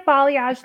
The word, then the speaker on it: balayage: So, like balayage, lived in color is mm-hmm.